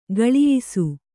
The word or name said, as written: ಖುಲಾಯಿಸು